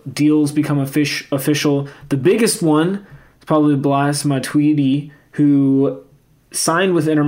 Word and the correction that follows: Blaise: We were reporting it as it's Blaise, right? I probably said his name wrong. My bad. – It is Blaise. – Blaise, I'm usually pretty good with the pronunciations, but I overthought that one.